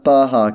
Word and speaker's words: Baja: It is pronounced Baja California, isn't it? – That's the only way I've heard it pronounced.